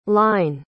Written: hline

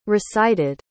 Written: recited

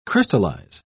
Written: crystallize

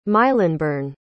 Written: Mylnburne

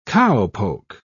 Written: cowpoke

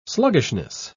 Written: sluggishness

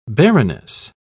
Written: baroness

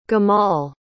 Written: gamal

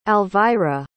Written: alvira

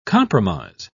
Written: compromise

